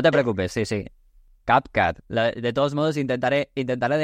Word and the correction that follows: CapCut: En TikTok me dicen, no se dice CapCat, se dice CapCut.